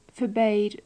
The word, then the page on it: forbade: English